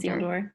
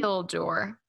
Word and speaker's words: Isildur: Now we're talking about Isildur. Is that how you say it? – Isildur. Isildur. Yeah. – Mm-hmm. Isildur. Like a sealed door.